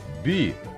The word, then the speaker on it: 匕: Слово «би» произносится в четвертом тоне.